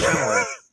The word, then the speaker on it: Chalamet: And one of these days, you will learn how to pronounce Timothy Chalamet's name. – Timothy Chalamet. Yeah, Chalamet.